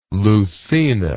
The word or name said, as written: leucaena